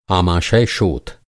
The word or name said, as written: आमाशय